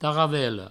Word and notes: taravela: provençal; taravello

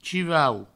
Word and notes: chivau: provençal

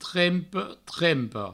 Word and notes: trempe, trempa: trempe, trempo

